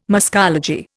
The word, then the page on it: muscology: Indian